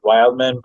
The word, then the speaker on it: Wyldman: My Instagram is at David Wildman, but Wildman is spelled W-Y-L-D-M-A-N because apparently everyone loves that as a nickname, Wildman.